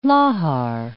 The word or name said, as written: lahar